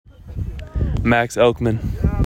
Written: Max Elkman

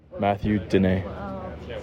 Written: Matthew Dinae

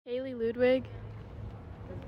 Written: Kailey Ludwig

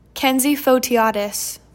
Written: Kenzie Fotiades

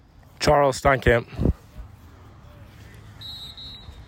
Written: Charles Steinkamp

